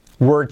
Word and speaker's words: we're: We're trying, fijaos cómo pronuncio were, were, como el pasado de we are, were.